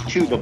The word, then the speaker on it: Chuba: His name's not Chuba. Chuba. Oh, is it Chuba? – It's Chuba, but I call him Chubba. – I'd rather call him Chubba, too, but Chuba, I'll call you Chuba.